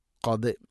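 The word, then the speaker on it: قد: ഖദ് സമി അല്ലാഹു, ഖദ് ഖാഫിൻ്റെ ഉച്ചാരണം തൊണ്ടയുടെ മുകൾ ഭാഗവും കഴിഞ്ഞ് ചെറുനാക്കിൻ്റെ ആ ഭാഗമാണ്. ഖാ ഖീ ഖൂ എഖ് എഖ് ഖ് ഖദ് സമി അല്ലാഹു.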